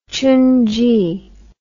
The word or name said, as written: xunzi